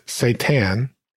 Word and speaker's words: seitan: But then I stumbled upon what is now my favorite protein source, which is seitan, and that's spelled S-E-I-T-A-N. And not the devil, Satan, but seitan.